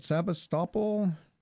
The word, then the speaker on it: Sebastopol: Sebastopol.